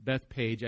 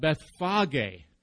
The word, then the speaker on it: Bethphage: For 41 years, I've pronounced it Bethpage. I never saw the H. I think all of yours have the H in there. That's why I hesitate when I read it. In the Greek, it's called Bethphage.